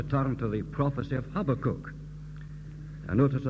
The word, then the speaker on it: Habakkuk: Then he said, if I were to pronounce this in Hebrew, I should say Habakkuk.